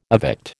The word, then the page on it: evict: American English